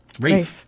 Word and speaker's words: Ralph: Because it is Liam Neeson versus Ralph Fiennes. – Rafe. – Oh, Rafe. God.